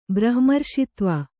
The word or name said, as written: ಬ್ರಹ್ಮರ್ಷಿತ್ವ